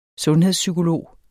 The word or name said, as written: sundhedspsykolog